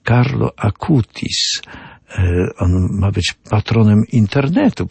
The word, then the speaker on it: Carlo Acutis: Carlo Acutis, czyta się, pisze się Carlo Acutis, bo to jest Włoch.